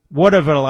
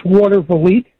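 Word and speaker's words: Watervliet: Let's go to Waterville. I think that's how you pronounce it. New York, the great WGD. How do you pronounce that? – It's Waterville.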